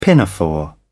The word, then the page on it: pinafore: British English